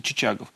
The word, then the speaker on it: Чичагов: Итак, адмирал Чичагов, да, ведь он же, его же там, ну, по-разному можно называть, так сказать, ударение, но принято Чичагов.